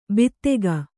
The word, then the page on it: ಬಿತ್ತೆಗ: bittega